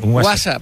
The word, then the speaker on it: WhatsApp: No, peor aún, con acento en la primera. WhatsApp, ¿no? – WhatsApp. – Ahí que, está.